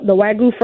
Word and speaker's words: Wagyu: You know what's cool about you is not only do you have Lexington Betty Smokehouse, but your Wagyu. Do I say that right? – Wagyu, yeah, you're pretty close. Wagyu beef.